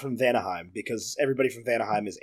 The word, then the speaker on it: Vanaheim: Thor, meanwhile, is on Vanaheim, which I was so disappointed to find out Ethan has been saying this wrong forever. – It was never written. And it was barely said, and so I thought it was Anaheim, and I'm sticking with Anaheim.